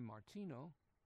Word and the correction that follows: Martirano: Martiano, keep mispronouncing his name, Salvatore Martirano, Salvatore Martirano, Roman Catholic of Italian extraction.